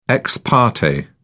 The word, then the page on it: ex parte: British English